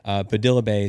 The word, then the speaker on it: Padilla Bay: And one of the ones right next to the tulip fields is Padilla Bay, or Padilla Bay. Padilla Bay is how it's pretty much pronounced in the area.